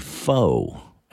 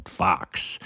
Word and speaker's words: faux: But it finally dawned on my dumb ass that he was trying to say faux, as in like artificial or vegetarian or whatever, which is spelled F-A-U-X. So I get it. He saw that and said fox.